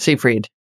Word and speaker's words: Seyfried: I don't know how to pronounce her last name. Seyfried? – Seyfried.